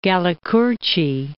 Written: Galli-Curci

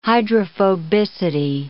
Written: hydrophobicity

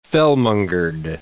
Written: fellmongered

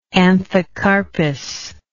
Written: anthocarpous

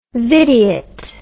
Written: videot

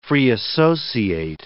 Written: free-associate

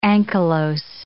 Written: ankylose

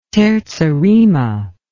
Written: terza rima

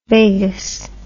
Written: Vegas

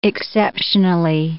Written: exceptionally

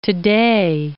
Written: today

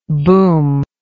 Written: boomless

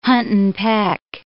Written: hunt-and-peck

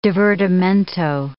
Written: divertimento

